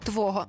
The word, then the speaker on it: твого: Дивись, якщо це слово йде саме по собі, тоді ми говоримо «твого». Наприклад, хочу вкрасти хвилину твого часу. Тоді наголос ми ставимо на останній склад «твого». А якщо перед цим словом поставити будь-який прийменник, наприклад, «довід», де звідки тощо, ми вже будемо мати наголос на «о» твого.